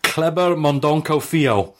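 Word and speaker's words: Kleber Mendonça Filho: It is Kleber Mondonko Fio.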